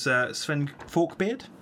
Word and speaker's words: Sven Forkbeard: So his father was Sven Forkbeard.